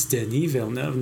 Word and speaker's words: Denis Villeneuve: And that's how you say his name, by the way, those who have never been able to say his name properly. It's Denis Villeneuve, not... – Denis Villeneuve. – Not yeah. Villeneuve or Villeneuve.